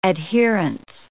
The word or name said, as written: adherence